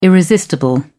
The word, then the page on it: irresistible: British English